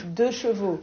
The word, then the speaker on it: deux chevaux: Deux chevaux, right? – Deux chevaux.